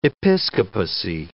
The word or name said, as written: episcopacy